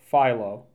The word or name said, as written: Philo